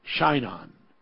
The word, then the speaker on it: Shinon: And I call it Shinon. And that's S-H-I-N-O-N.